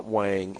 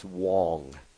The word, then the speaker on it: Huang: It's not Wang, it's Wong. It's Jensen Huang.